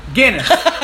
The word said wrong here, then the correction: Giannis: Giannis. I'm sorry. Not Guinness. – Monte DeCumbo. – Giannis. – Is that how you say it? – Yeah, something like that. Giannis, though.